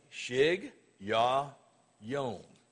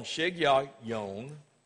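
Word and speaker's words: Shigionoth: Here's the way it's pronounced in the Hebrew. It's pronounced this way. Shig Yah Yom.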